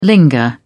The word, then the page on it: linger: British English